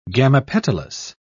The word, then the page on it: gamopetalous: British English